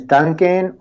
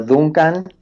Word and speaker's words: Duncan: Well, my name is Duncan, or Duncan Tonatiu. In Spanish, you can pronounce my name Duncan.